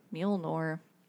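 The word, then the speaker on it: Mjölnir: Mjolnir. How do you say the hammer? – Mjolnir.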